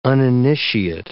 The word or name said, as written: uninitiate